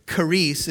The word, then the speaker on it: charis: It's charis. It's spelled C-H-A-R-I-S, charis.